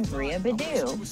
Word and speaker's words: Bria Beddoe: Hey, I am Bria Beddoe, and thank you for having me on your show.